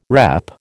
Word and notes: repp: American English

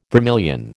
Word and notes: vermilion: American English

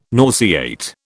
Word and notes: nauseate: British English

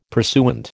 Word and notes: pursuant: American English

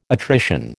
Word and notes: attrition: American English

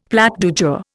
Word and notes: plat du jour: Indian

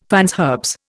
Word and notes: fines herbes: Indian

